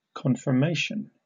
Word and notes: conformation: British English

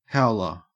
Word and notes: howler: Australian English

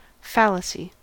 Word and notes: fallacy: American English

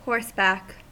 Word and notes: horseback: American English

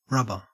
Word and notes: rubber: Australian English

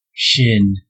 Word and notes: shin: American English

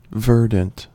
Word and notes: verdant: American English

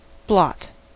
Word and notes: blot: American English

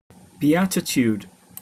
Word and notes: beatitude: British English

